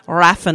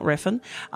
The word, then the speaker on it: Refn: And how you say his name? Raffin, not Refn.